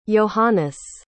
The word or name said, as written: johannes